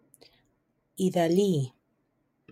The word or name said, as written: Idali Vega Sanchez